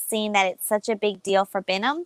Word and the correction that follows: Binyam: That, am I saying his name right? We always say Binyam. – Binyam, that's it.